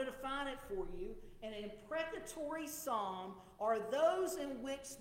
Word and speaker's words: imprecatory: Each of these statements would fit well into the genre of the Psalms that scholars call the imprecatory Psalms. And I'm going to spell that for you because you may, it sounds like I said impregnated Psalms. And no, that's not right. It's imprecatory. I-M-P-R-E-C-A-T-O-R-Y.